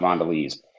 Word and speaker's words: Mondelez: It's pronounced Mondelez. – Mondelez. – It's French. I know how to pronounce Mondelez.